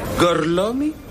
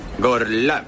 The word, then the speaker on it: Gorlami: Gorlomi? Lo pronuncio correttamente? – Sì, è corretto. – Gorlami? – Gorlami. – Mi scusi, com'è? – Gorlami. – Ancora una volta. Gorlami.